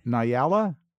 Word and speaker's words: Nyala: So I guess it's pronounced Nyala, N-Y-A-L-A, Nyala.